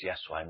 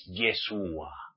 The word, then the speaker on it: Yahshua: Si no sabéis pronunciarle bien el nombre, está escrito en su verdadera escritura en el manual de Yahweh, que su nombre auténtico es Yahshua, no es Yeshua, como lo pronunciáis mesiánico.